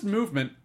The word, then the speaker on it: MVMT: MVMT watches, pronounced movement, if you were wondering. They took the vowels out. It's pronounced movement watches.